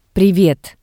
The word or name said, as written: Privet